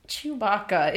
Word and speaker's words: Chewbacca: Chewbacca. It's three syllables.